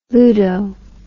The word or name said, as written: ludo